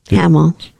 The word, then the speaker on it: Hamill: So this is an interview Mark Hamill. Kimmel. – Hamill. – Hamill. Sorry. I've never been able to get his name right.